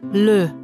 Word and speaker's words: le: Das E ohne Akzent wird mit gerundeten Lippen mit Tendenz zu einem gehauchten, kurzen und offenen Ö gesprochen. – Le, je, venir, retour.